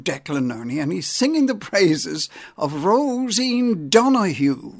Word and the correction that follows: Rosie Donoghue: Rosie Donoghue. I'll get the name right, Joe.